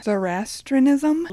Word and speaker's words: Zoroastrianism: So to dive into it, Satan first became a figure, or was first seen as a figure, in Zoroastrianism. – Love that word. – Probably butchered that word. – I think you did great.